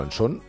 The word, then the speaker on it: Anson: No, pero Anson, pero bueno, en todo caso sí se pronuncia así, ¿no? – Se pronuncia así, claro. – Aunque no lleve la tilde por ser originalmente british.